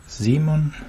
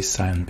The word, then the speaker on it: Simon: This is Simon, as is the pronunciation in German, of course, of my name, but I'd rather like to say Simon because I don't know why, but people think it sounds cool, like, yeah, in Argentina.